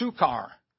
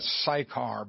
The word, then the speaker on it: Sychar: Verse 5, then cometh he to a city of Samaria, which is called, I've been pronouncing that wrong all these years, it's Sukkar. Sukkar. I always pronounce that Sychar, but it's Sukkar, near to the parcel of ground that Jacob gave to his son Joseph.